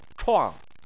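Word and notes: chuang: tone 4

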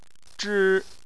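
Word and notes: zhi: tone 1

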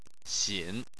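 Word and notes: xin: xin3; tone 3

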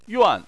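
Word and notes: yuan: tone 4

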